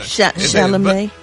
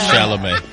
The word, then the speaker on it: Chalamet: Oh, that's how you say it. – Yeah, Chalamet. – Chalamet. – You was close, though. You say you ever thought about going back? You ever thought about going back to kindergarten? – It's like Chevrolet. Oh, not Chevrolet.